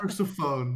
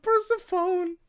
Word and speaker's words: Persephone: Persephone, yeah. Persephone.